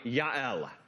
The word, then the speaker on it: Jael: There's no J in the Hebrew alphabet. Her name is spelled and pronounced with a Y, Jael.